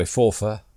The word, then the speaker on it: Forfar: We like to pronounce it properly, don't we? Forfar.